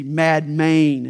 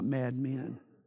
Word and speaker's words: Madmen: Madmain is how it's pronounced, not madmen.